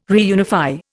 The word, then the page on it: reunify: Indian